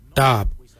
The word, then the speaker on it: Dhaab: Today we will look at, for knife we say darb. Darb is spelled D-H-A-A-B. It is a traditional knife, a darb.